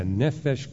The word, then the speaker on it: nefesh: the animals that are nefesh? Endowed with... – Is that how you say it? – Yeah, nephesh creatures, which nefesh, you see in yeah.